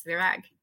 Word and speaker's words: Virág: Yes, Virág.